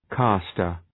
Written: castor

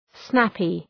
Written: snappy

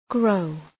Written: grow

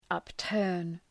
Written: upturn

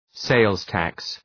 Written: sales tax

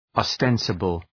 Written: ostensible

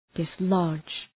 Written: dislodge

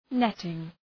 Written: netting

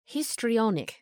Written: histrionic

